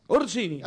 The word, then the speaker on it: Orsini: Orsini. Orsini. – Ahí está, así, se, dice.